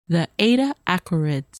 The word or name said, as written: Eta Aquarids